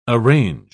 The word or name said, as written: arrange